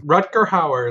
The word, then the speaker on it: Rutger Hauer: Rutger Hauer is how I've always heard it.